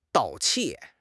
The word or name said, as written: 盗窃